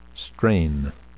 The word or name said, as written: strain